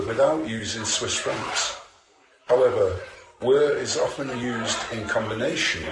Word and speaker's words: WIR: The Vir Bank, that's pronounced Vir, W-I-R, Bank,